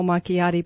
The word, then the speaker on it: macchiato: Well, you mentioned the caramel macchiato, but the white chocolate mocha is really worse than the caramel macchiato.